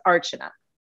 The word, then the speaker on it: Archana: my name is Archana.